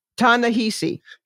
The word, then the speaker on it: Ta-Nehisi: Ta-Nehisi.